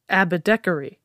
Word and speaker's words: Abecedary: Oh, it looks like Abecedary. – It does. Actually, I definitely thought it was pronounced differently when I first started looking into it. – But it is definitely pronounced Abecedary.